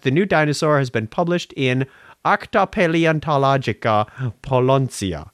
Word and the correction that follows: Polonica: Oh, I'm sorry, not Polonia. It's Polonica.